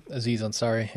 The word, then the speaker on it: Aziz Ansari: 7 Days of Hell, Master of None, the Netflix series, the Aziz and Azari. Is that how I pronounce his name? – Aziz Ansari, yeah.